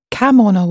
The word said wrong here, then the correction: kaimono: wa. – Kaimono wa. – Oh, it's the two syllables.